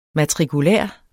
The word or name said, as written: matrikulær